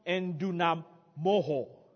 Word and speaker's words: endunamoo: That word strong in the Greek is spelled E-N-D-U-N-A-M-O-O. It's pronounced endunamoho.